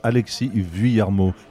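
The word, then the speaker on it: Alexis Vuillermoz: I mean, it's Alexis Villamoz. You don't pronounce either the S or the Z.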